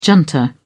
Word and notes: junta: British English

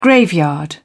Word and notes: graveyard: British English

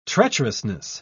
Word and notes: treacherousness: British English

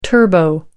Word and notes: turbo: American English

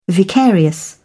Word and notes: vicarious: British English

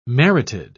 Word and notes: merited: British English

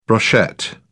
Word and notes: brochette: British English

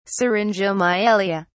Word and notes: syringomyelia: British English

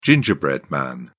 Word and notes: gingerbread man: British English

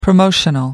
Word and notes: promotional: American English

